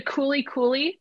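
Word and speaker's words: Kuli Kuli: So you can go to Cooley Cooley, their website, and look for the Super Moms campaign. – How do you spell that? Cooley? – Yeah, it's K-U-L-I, K-U-L-I.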